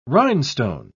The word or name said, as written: rhinestone